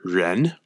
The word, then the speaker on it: ren: But I know it is in fact due to your humanity, your ren, R-E-N, in modern day Chinese pronunciation and transcription, your goodness, your humanity.